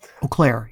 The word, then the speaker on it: Eau Claire: Eau Claire, yep.